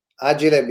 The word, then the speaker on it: Agile: Agile because it's an Italian company, that's how we pronounce Agile.